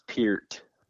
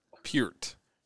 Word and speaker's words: Peart: Uh, it's Peart. Peart.